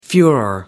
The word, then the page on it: furore: British English